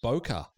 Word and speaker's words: bokeh: And I think it's really important to point out that that's how you pronounce. Wait, but bokeh. That's how it's actually pronounced. Is that right? – It's like a bokeh bowl.